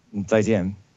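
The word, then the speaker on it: Zaijian (再见): Zaijian.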